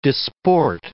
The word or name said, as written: disport